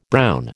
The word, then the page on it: brown: American English